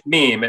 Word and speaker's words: meme: So that was number one, the meme stock. I had a listener wrote in, he's like, I said, mem, I don't know what I was calling it, but he's like, it's meme. It rhymes with theme.